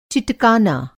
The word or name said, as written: चिटकाना